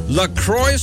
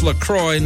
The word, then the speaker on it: LaCroix: Officially pronounced LaCroix, not La Croix, like the French might say it.